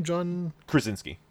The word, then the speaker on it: Krasinski: Uh, John, sorry, how do I say his last name? John Krasinski. – Krasinski, thank you.